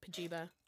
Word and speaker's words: Pajiba: For Pagina. Pajiba. I'm sorry. – Pajiba? – Pagina? – Pagina? – Pagina? I call it Pagina. – Pajiba. – Well, it's not because it says pronounced with a long I, so who the fuck Pajiba.